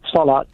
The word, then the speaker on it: Salat: And the second one is Salat. Is that how you say it? – Salat. Yeah, Salat.